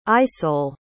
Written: isole

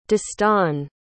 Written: dastan

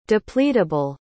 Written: depletable

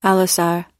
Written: allosaur

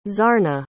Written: zarna

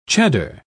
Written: cheddar

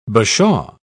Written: bashaw

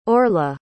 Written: orla

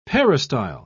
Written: peristyle

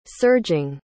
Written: surging

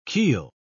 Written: keel

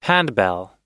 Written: handbell

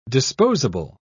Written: disposable

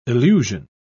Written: illusion

